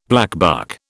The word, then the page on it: blackbuck: British English